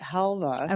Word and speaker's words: halva: Is it halva? – We pronounced it halva.